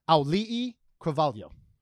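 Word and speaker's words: Aulii Cravalho (Auliʻi Cravalho): And it is Aulii Cravalho.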